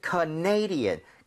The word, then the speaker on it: Canadian: 왜냐하면 캐나다는 Canada 이렇게 가는데 네. – 이게 캐나다 사람 이러면은 Canadian 강세가 뒤로 하나 밀려요. – 아. – 그래서 살짝 헷갈릴 수 있습니다. – Yes. – 그래서 Canadian, Canadian.